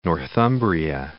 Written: Northumbria

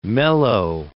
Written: mellow

